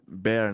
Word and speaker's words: Bear: Kuma, Kuma, Kuma, Beer. É Beer, é Bear, né? Bear de urso, ó.